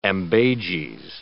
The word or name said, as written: ambages